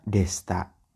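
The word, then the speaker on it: Desta (でした): Kako Desita. Desita. Bacanya Desta.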